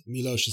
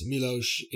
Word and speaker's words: Miłosz: For English-speaking readers, at least, Miłosz has not benefited from having the most straightforward name. To our listeners, Miłosz is spelled M-I-L-O-S-Z for those on Google.